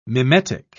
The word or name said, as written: mimetic